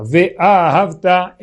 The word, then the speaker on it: Ve'ahavta: Inclusive, aproveitando, quando a gente lê o Kriyat Shema, um detalhe gramatical muito curioso, se a gente lê Ve'ahavta, a gente está falando que a gente amou Hashem. O certo, a pronúncia certa é que essa palavra ela é oxítona. Ve'ahavta é um imperativo de amar Hashem.